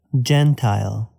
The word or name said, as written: Gentile